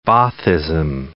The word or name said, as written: Baathism